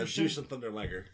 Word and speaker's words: Jushin Thunder Liger: Thunder, is it Jushin Thunder Liger? Is that how you pronounce it? I know he's going in. I don't know how you pronounce it. J-U-S-H-I-N. – Yeah, Jushin Thunder Liger.